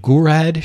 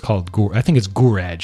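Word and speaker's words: Gouraud: Now, on top of this, the engine supported dynamic lighting, morphable terrain, and GURAD shading. I think it's GURAD shading. G-O-U-R-A-U-D. I've never really known how to pronounce this properly, but I'll go with GURAD.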